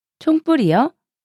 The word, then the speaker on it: 총부리: 총뿌리요? 발음은 총뿌리이지만 철자 스펠링은 총부리이잖아요.